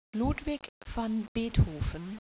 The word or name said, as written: Ludwig